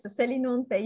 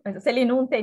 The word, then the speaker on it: Selinunte: Yes, Selinunte is correct. In English is Selinus, Greek is Selinus, Selinunte in Italian. – Could you tell us about some of the work that you did at Selinunte?